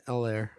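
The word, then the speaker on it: Helaire: You don't have a whole pronounce the H. It's Allaire. – It's silent.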